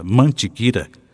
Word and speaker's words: Mantiqueira: A palavra mantiqueira, também escrita e pronunciada mantiquira, ainda não foi interpretada.